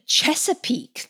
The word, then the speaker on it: Chesapeake: Okay, this one comes from Aaron in Chesapeake.